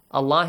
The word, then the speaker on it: Allah: Allah is pronounced with an a sound.